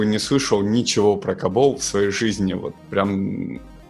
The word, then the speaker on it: COBOL: И не просто программисты, а программисты на языке, который читается как Кабол.